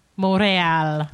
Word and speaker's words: Montreal: But, I mean, they could do something like PAX Montreal, but that's so close to Boston. – Wait, say it right. – Montreal. – There we go.